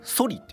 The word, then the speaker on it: ソリ: ソリって読むんですよ